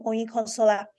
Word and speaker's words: Oyinkansola: But I always insist on people pronouncing my name Oyinkansola, even when they're like, oh, I can't, it's long.